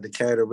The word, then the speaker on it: Decatur: Yeah, Decatur.